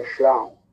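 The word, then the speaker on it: Jean Bodin: bisa nulisnya bukan nyeratkan bu begitu biasa mah bahasa Prancis ya D E A N j Jean ya itu nama Perancis ya tapi dibacanya Shang B O D I N Bodin dibacanya Bodang dua tokoh ini yang mengembangkan positivisme hukum.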